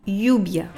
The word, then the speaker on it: lluvia: La pluie, on dit lluvia. Lluvia. En accentuant sur le u.